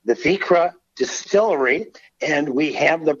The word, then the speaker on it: Vikre: So it's spelled V-I-K-R-E. Vikra,